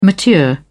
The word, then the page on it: mature: British English